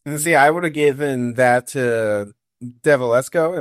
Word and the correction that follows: Delvescovo: am I saying that right? Max from I think it's Delvescovo.